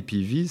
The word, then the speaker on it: PIWI: PV, ça s'écrit PIWI, et c'est effectivement un acronyme pour des cépages résistants aux maladies.